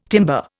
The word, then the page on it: timber: Indian